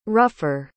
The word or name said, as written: rougher